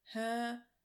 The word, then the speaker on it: Hermione: es versucht, ihm beizubringen und dann wirklich Hermione.